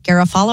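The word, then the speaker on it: Garafolo: According to Tom Pelissero, Adam Schefter, Mike Garofalo, I don't even know how to say his last name. – You hit it.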